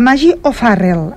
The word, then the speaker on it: Maggie O'Farrell: És la Maggie, perdoneu-me per les pronúncies, és la Maggie O'Farrell, ara sí que ho he dit bé.